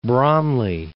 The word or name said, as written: Bromley